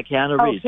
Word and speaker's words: Keanu Reeves: How do you pronounce his name? You know, the guy that did the Matrix? Keanu Reeves? – Yeah, Keanu Reeves.